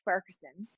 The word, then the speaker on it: Farquharson: So my last name is Farquharson.